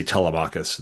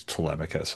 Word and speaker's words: Telemachus: I used to say Telemachus and somebody's like, no, it's Telemachus.